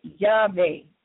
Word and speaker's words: Yahweh: And we pronounce it Yahweh, Y-H-V-H.